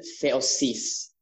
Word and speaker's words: theosis: Okay, so I think to use the proper pronunciation, theosis, is how you pronounce it in Greek.